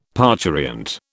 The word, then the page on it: parturient: British English